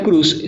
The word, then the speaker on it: Croes: También está con nosotros los amiguis de Camila Cruz, se escribe Croes.